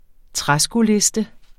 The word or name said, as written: træskoliste